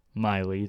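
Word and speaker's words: Miele: Miley, that's how you pronounce it. It's a weird spelling.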